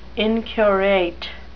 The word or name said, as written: inquorate